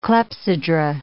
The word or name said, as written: clepsydra